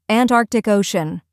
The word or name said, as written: Antarctic Ocean